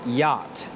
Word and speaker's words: yacht: The next word is yacht. This is a difficult word because it has two letters that are silent. The two letters are C and H.